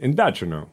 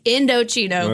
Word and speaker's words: Indochino: I'm pretty sure it's pronounced Indochino. – They gave us explicit instructions. It's Indochino.